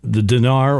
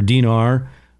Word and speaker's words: dinar: And ours is a fiat, being ours is a fiat currency, so the dinar or dinar would have been a serious threat, especially if those oil countries started selling their oil in dinara. There, I gave you all 3 pronunciations.